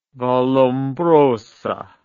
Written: Vallombrosa

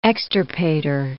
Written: extirpator

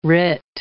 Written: writ

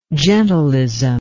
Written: gentilism

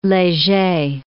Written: Leger

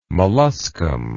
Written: molluscum

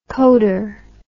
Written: coder

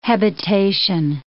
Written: hebetation

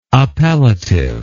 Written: appellativeness

